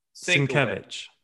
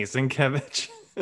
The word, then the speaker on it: Sienkiewicz: Sienkiewicz. Repeat it after me. Sienkiewicz.